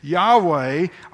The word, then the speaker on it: Yahweh: And in more recent years, you'll hear it almost always said Yahweh, although I emphasize nobody knows for sure how to pronounce it, because if you put the vowels, it'd be like putting the vowels from Missouri into the word Arkansas.